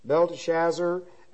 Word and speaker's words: Belteshazzar: Now, Daniel means God is judge, and they changed his name to Belshazzar, Belteshazzar, Belteshazzar. Now, not Belshazzar the king. There's an extra syllable in here. Belteshazzar. And this comes from Bel, B-E-L.